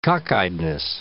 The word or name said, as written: cockeyedness